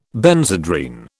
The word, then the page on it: Benzedrine: British English